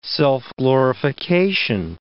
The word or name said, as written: self-glorification